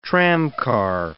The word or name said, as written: tramcar